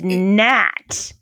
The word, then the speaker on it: gnat: And it's gnat. – Yeah, you wouldn't think to change the O in goat to something that makes it a silent G, right? – Yeah, yeah.